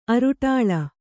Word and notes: ಅರುಟಾಳ: aruṭāḷa

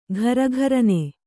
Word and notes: ಘರಿಘರಿನೆ: gharigharine

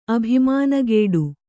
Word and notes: ಅಭಿಮಾನಗೇಡು: abhimānagēḍu

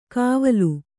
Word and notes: ಕವಲು: kannada

